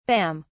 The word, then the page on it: bam: British English